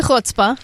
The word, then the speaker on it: chutzpah: Speaking in front of a crowd, even, I mean, any crowd, actually takes a little bit more chutzpah, I should say, or chutzpah, or however you pronounce that. That would be chutzpah, yes. – Yeah, okay, I'm from the Midwest. CH means one thing and one thing only.